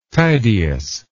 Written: Tydeus

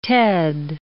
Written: ted

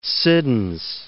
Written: Siddons

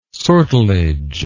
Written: sortilege

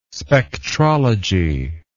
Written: spectrology

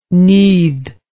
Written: nudd

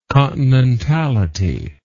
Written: continentality

